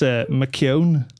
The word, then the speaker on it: McKeown: Not even close. So it's McKeown. – Oh, McKeown. – Yeah. But it's just two syllables.